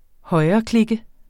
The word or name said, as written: højreklikke